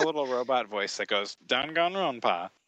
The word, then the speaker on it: Danganronpa: Danganronpa.